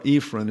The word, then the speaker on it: Ephron: The shekel was a rolled piece of metal first mentioned in Genesis chapter 23, where Ephron is the correct pronunciation, where Ephron sold Abraham the field, including the cave, in which he buried his wife Sarah.